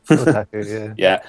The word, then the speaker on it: otaku: Is that how you pronounce it? Otaku? – Otaku, yeah.